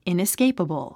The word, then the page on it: inescapable: ìnəskéipəbl; イナスケイパボォ